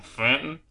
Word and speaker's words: Fenton: And it made each stylistic choice, I think, like, having Fenton, and I couldn't figure out his name, if it was Finn or Fenton, because everyone pronounces it like a Texan, which is like E's or I's. – No, Fenton.